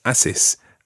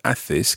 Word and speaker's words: haces: Now, if you think about that word, Athes, okay, athis, in actual fact, there's a silent H at the beginning of that word. Athis is spelled H A C E S.